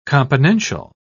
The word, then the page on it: componential: British English